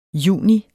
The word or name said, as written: juni